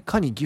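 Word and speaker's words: 可児: まあ、つまりドラゴンズの、こう、まあ、フランチャイズエリアに近いところっていうことになりますが、帝京大カニ、岐阜のカニですね。あの、加納のカに、児童の字でカニって読むんですけども、こちら出身の高校生のピッチャー。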